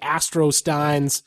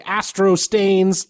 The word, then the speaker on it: Astro Steins: This week's music comes to us from listener John Uribe and his band, the Astro Steins. They describe themselves as, quote-unquote, a rock band. – Okay. – Out of El Paso, Texas. They write, some people mispronounce our name and say Astro Stains, lol.